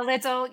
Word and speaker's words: Lidl: Lidl, is that how you say it? – Pretty much all of the supermarkets. Yeah, Lidl. Yeah, so they're the three biggest poultry producers in the country.